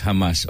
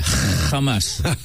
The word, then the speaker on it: Hamas: All right, so what about the terrorist Hamas? Or maybe I should pronounce it better. Hamas.